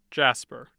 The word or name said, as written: jasper